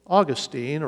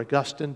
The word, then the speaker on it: Augustine: So, Augustine, or Augustine, depending on how you want to pronounce it, Augustine was a profligate.